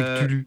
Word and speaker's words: Cthulhu: Voilà, c'est Call of Cthulhu. Tiens, non, ça se prononce en anglais comme ça. – Ben on va aller les voir. – Et en français, c'est Cthulhu.